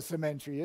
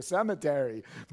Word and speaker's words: cemetery: You know what a cementry is, don't you? It's a cemetery. But they say cementry. So I just think, second syllable.